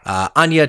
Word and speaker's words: Anya: Anya Taylor-Joy as Sandy, and I said it right this time. I'm sorry for anyone who listened to the New Mutants episode where I called her Anna Taylor-Joy multiple times, and no one corrected me.